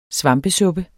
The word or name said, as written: svampesuppe